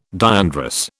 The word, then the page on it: diandrous: British English